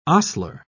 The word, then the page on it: ostler: British English